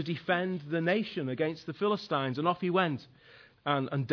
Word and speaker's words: Philistines: that's Philistines to you, Dan, it's the correct pronunciation is Philistines.